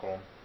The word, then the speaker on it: Köln: Like I remember when I visited Germany and I got to go to Cologne, or in German, pronounced Köln.